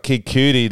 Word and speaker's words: Cudi: I meant like cutie, like C-U-D-I. Okay, Kid Cudi filled in.